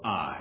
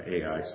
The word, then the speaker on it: Ai: And right after it, this embarrassing defeat at this little town, not a mighty walled fortress, this little town of Ai. That's how you would properly pronounce it, but everybody knows it seems by Ai, so that's how we say Ai.